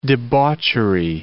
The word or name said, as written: debauchery